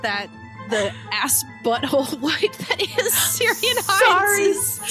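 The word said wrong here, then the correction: Ciarán: His name is actually pronounced Kieran.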